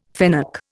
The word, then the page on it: fennec: Indian